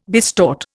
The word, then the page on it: bistort: Indian